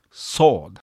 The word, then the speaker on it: sword: ジョン・ブラウンズ・バディ 4 番の 3 行目 He's loosed the fateful lightning of his terrible swift sword。この発音、特に最後の 2 つの単語ですね、 swift sword、どちらも SW で始まっておりますが、最初の swift の方は W をちゃんと発音します。それに対して次の刀という意味の sword、この単語からは W の音が全く消えてしまいます。